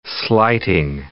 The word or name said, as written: slighting